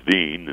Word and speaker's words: Wien: Wieners, we get that word from the capital of Austria, Vienna, which the Germans pronounce as Wien in their language, W-E-I-N, and that's where the word wieners comes from.